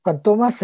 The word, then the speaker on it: Fantomas: Eh, y, y Fantomas, en realidad, así se pronuncia, Fantomas eh,